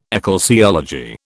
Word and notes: ecclesiology: British English

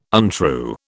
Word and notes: untrue: British English

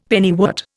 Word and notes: pennywort: Indian

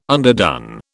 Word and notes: underdone: British English

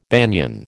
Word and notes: banian: American English

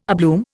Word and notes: abloom: Indian

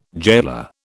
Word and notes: gaoler: British English

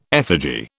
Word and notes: effigy: British English

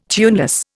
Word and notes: tuneless: Indian

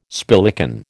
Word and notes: spillikin: American English

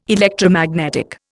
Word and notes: Electromagnetic: Indian

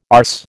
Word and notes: arse: American English

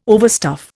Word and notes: overstuff: Indian

